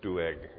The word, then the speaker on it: Doeg: So they're just quiet before Saul, all except Doeg, which is, there's different pronunciations of that, by the way, but it usually would have an apostrophe in the middle of it, so it's Doeg, it's two syllables in there, in case you're thinking, boy, is that weird the way he's saying that.